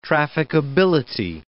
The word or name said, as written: trafficability